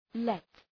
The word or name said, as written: let